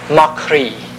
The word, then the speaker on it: croidhe: So Cri, in Gaelic, is actually, just for fun, it's spelled C-R-O-I-D-H-E. The H makes the D disappear, yeah, or not be pronounced.